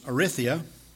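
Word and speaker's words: eritheia: I think the pronunciation for this Greek word is erithia. It's E-R-I-T-H-E-I-A. E-R-I-T-H-E-I-A.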